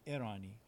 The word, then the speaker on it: ارانی: تلفظ اسمش رو میگه ارانی،